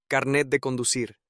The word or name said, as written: carnet de conducir